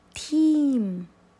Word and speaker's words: 添: 接下来我们今晚学最后一个语气词，这个语气词呢跟前面那几个是一样的，属于其他语气，它的发音是 team team，写法呢是添，添加的添。